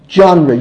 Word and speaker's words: genre: And throughout the hundreds of years following the Gemara, you don't really have a separate genre, if I'm pronouncing the word right, correct me if I'm wrong. How do you pronounce it? – Genre.